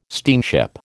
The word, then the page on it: steamship: American English